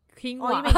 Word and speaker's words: quinoa: How do you spell it? – Q-U-I... – Quinoa. Oh, you mean quinoa. – Did you pronounce it quinoa? Okay, I pronounce it quina.